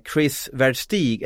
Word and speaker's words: Chris Verstig: En av säsongens större värvningar, den lyckades Växjö dra hem i veckan när man lyckades skriva korttidskontrakt med Chris Verstig. Är det korrekt uttalat, Tellan? – Ja, det låter rätt.